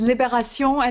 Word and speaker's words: Libération: It's Libération, and we always refer to it as Libé.